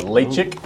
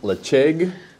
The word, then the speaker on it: Ledaig: It's a Lechig, or Lechig, depending on how you pronounce it. L-E-D-A-I-G.